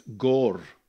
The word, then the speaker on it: Gore: Many years ago, we had a couple in our church who came from, I don't even know how to say this, Gore, is that right? In the South Island of New Zealand. And you have to roll the R somehow on the way through.